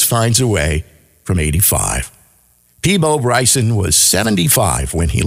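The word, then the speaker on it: Peabo: Freeman's frequent mispronunciation of Bryson's French West Indian name, Peepo, as Peebo, led the singer to adopt the mispronunciation as his stage name.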